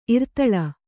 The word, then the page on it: ಇರ್ತಲೆ: irtale; Kannada